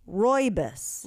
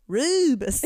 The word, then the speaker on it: Rooibos: It's Rooibos. – Rooibos, and it's South African. – I thought it was Rubus. Like, look at the way it's spelled. – Yeah, that looks like a kid's show name. – Rubus. I thought it was Rubus. Apparently, it's Rooibos.